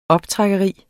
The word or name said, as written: optrækkeri